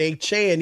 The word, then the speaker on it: Achane: Devon Achane, that's how you say it, Achane.